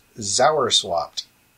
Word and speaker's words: zowerswopped: The word is Zauerswapt.